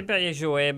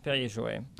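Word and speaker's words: Perrier-Jouët: Anyway, this one, the first thing you hand me is Perrier Jouet. Or Jouet, I think, is how it's said, isn't it? Or I say Perrier Jouet, but some people say Jouet as well. It depends, right.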